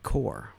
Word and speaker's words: corps: Scott, it's not pronounced like corpse. It's pronounced like core. – That's right. It is. – Really? – It's core.